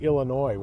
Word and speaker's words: Illinois: I thought it might even was like Illinois, where you don't pronounce the S.